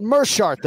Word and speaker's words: Meerschaert: Gerald Meerschaert, though, for the broadcast this weekend. It is not Meerschaert, despite that double E in the first syllable of his last name.